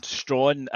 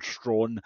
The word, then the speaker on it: Strachan: His name is Gordon Strachan. But in that softening of European pronunciations that you see so often in America, he becomes Gordon Strachan.